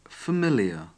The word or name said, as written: familiar